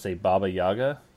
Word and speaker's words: Baba Yaga: And then Baba Yaga. Is that how you say it? – Yeah, that's correct.